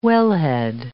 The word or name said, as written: wellhead